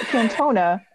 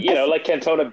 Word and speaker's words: Cantona: What do Gareth Bale, Eric Cantona, and Joe Mason have in common? – Eric who? – Cantona. – Yeah, that's right. – Like Cantona Beach.